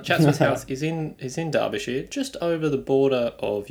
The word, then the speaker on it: Derbyshire: Derbyshire.